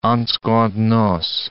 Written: en secondes noces

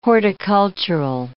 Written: horticultural

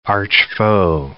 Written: archfoe

